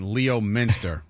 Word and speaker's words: Leominster: And Leominster isn't Leo Minster, right, Lisa? – I spent a lot of time in Leominster.